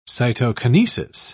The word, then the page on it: cytokinesis: English; British English